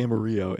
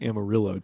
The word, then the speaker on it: Amarillo: I pronounced it Amarillo. It's actually Amarillo, Texas.